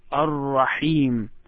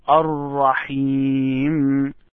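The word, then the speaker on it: Ar-Rahim (الرحيم): I would like you to pronounce Ar-Rahim. Ar-Rahim. Ar-Rahim. So just try to say this word. Ar-Rahim.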